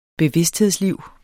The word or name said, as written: bevidsthedsliv